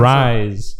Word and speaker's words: Rise: Is it Reese? Is that how you pronounce Rise. – It? Rise. Rise? Okay.